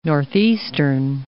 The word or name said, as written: northeastern